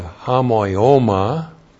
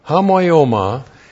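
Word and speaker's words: homoioma (ὁμοίωμα): And then we have homoioma. Looks like that in the Greek, H-O-M-O-I, long O-M-A, homoioma, and that means likeness, and the dative case is the dative of sphere, in the likeness, to thanatou autou, of his death.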